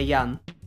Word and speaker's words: Ян: И в одном из шоу пару лет назад э, Стивен Ян сказал, что вы все неправильно произносите мою фамилию, я как бы я Ян.